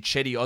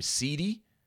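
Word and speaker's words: Cedi: Okay, it's obviously Chetty Osman or Jetty Osman, but I'm just taking the piss of people who mispronounce names, and someone told me that they had someone who legitimately would call him CD, which is obviously CD, compact disc.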